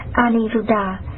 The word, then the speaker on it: Aniruddha: Ngài Anulauda, Ấn Độ đọc là Aniruddha,